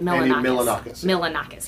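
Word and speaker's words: Milonakis: Am I saying it right? – Milanakis.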